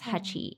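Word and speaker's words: Hachi (八): and he thought that they resembled the kanji or the character that represents the number eight and is pronounced as Hachi in Japanese.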